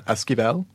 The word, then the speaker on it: Esquivel: Esquivel, which is E-S-Q-U-I-V-E-L.